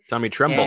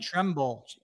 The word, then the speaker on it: Tommy Tremble: Tommy Tremble. – And, Tremble.